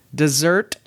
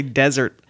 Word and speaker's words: dessert: Dessert first. I kept wanting to say desert.